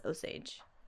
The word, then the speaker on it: Osage: about the Osage murders that took place in the 1920s. Is it Osage? – Yep. – Yeah, because I've heard some people mispronounce it, and I was like, I think it's Osage.